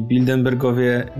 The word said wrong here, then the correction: Bilderberg: Jak, jak to się wymawia? Bill. Bildenbergi. Bildenbergi. Po prostu mówisz szybko. Yy, po prostu znaleźli kolejny sposób, aby... Bilderbergi. Yy, a przez R.